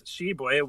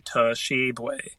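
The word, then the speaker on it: Tshiebwe: But or if you have trouble with Shibwe, which, God, why would you? It's a two-syllable name. – Mm. – Just call him Big O, like Jimmy Dykes does. It's okay. Yeah. Don't say Toshibwe. That just drove me crazy.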